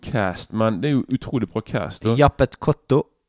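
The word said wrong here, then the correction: Yaphet Kotto: no, det navnet som Pål aldri klarer å uh, Klarer å uttale skikkelig. – Mm. – Men du, du, du kan det nå, vel? Jappet Kotto. Jaffet Kotto. Jaffet Kotto. Jaffet Kotto, ja. som han heter.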